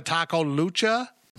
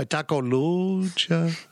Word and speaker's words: Lucha: We're sponsored by Taco Lucha. Hold on. I didn't say it right. By Taco Lucha.